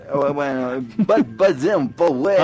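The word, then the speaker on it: Baden Powell: Baden Power. Bosa and son brasileños, no lo pronuncie tan inglés. – Baden Power.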